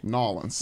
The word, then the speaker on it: New Orleans: Nolens.